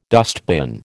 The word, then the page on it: dustbin: American English